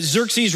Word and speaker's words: Xerxes: I just always reminded myself of this when I was trying to remember all the kings in seminary, that Xerxes rhymes with Jerxes.